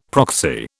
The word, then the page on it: proxy: British English